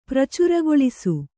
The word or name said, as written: ಪ್ರಚಾರಗೊಳಿಸು